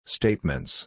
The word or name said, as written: statements